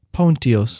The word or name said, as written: Pontius